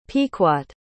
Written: pequot

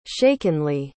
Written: shakenly

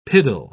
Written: piddle

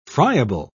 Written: friable

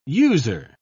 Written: user

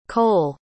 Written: kole

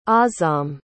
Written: azam

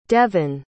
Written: devynn

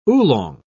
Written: oolong

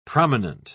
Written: prominent

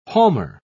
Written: palmer

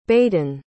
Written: baden

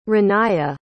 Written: Raniya

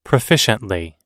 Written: proficiently

